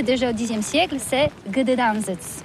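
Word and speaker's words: Gdańsk: Oui, c'est pas très facile pour des étrangers, mais remarque bien que la langue polonaise, c'est la langue où on prononce chaque lettre qu'on écrit. C'est pourquoi on dit Gdansk.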